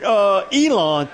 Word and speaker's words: Elon: The quarterback from Elon, if I'm pronouncing it right, E-L-O-N? – Yeah, Elon.